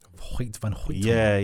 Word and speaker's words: Hoyt van Hoytema: I think the cinematography was done by, let me say his name right, Hoyt van Hoytema. – Hoyt van Hoytema.